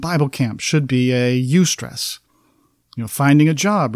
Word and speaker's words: eustress: When I say eustress, that's spelled E-U-S-T-R-E-S-S, eustress.